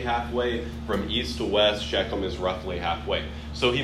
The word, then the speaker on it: Shechem: Yeah, it's pronounced Shechem.